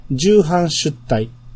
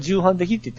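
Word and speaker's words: 重版出来: 重版出題。 – はい。 – っていうタイトルね。 – みんな重版できって言ってませんでした？ – いや、読み方がわからんけど、重版出題って読むんやっていうのはあの時にみんな学んだと。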